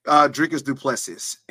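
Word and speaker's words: Dricus du Plessis: And then your buddy, Sean Strickland, going against Dricus Plessis. Ain't that how you say it? – Dricus Duplessis. That is going to be a banger of a fight.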